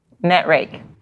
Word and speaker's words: Netrake: And before that, she was director of HR at, and you're going to have to pronounce this, Netrake? – Netrake. – Netrake. Boy, did I mess that up.